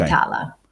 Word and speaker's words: Vitala: That's V-I-T-A-L-A. So it's like vital, but with an A at the end. Okay. Vitala.